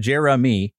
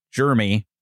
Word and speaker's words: Jeremy: So, yeah, but I understand the multiple syllable name because really my name's supposed to be pronounced Jeremy with three syllables. Uh-huh. And for years, it's been a two syllable Jeremy. Like, no, I'm not loaded with bacteria.